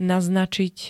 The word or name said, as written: naznačiť